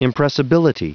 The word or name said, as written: impressibility